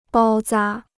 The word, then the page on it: 包扎: bāo zā